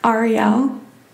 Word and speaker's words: Arielle: Arielle.